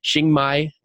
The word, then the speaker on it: Chiang Mai: And as we said, he's based out of Denver, but he's currently living in, I'm going to say this and butcher it, Chiang Mai, Thailand.